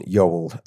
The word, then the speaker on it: Yeowell: It's at Nathan Yeowell.